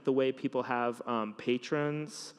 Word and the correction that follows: Patreon: now, is that how you say it, or Patreon? – Patreon.